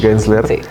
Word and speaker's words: Gensler: Es, Gensler.